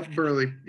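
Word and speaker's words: Buehrle: Burley? I never know how to pronounce that. – Yeah. Yeah, you had it right that time. – Yeah, Burley.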